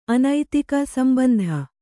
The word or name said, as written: ಸಂಬಂಧಿ